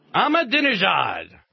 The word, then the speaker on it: Ahmadinejad: Ahmadinejad. – Yeah, Ahmadinejad. – I do believe that's how you pronounce it.